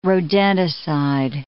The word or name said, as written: rodenticide